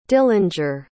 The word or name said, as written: dillinger